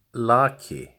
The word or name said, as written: Laki